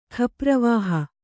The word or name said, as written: ಖಪ್ರವಾಹ